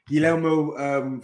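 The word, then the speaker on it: Guglielmo: So Guillermo, Guillermo Vicario, right? Because the second G is silent, right? – Yeah.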